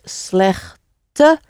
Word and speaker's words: slechte: So that is bad information would be dat is slechte informatie. So the E always gets pronounced in Dutch at the end of a word as an E. So again, that unstressed sound.